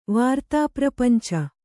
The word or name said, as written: ವಾರ್ತಾಪ್ರಪಂಚ